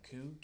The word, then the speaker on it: Kue: You find me on Facebook by my name, Vu Koo. – Spelled. – How do you spell it? – Yeah. – V as in Victor, U as in Unique, E as in Edward. Last name Koo, K-U-E.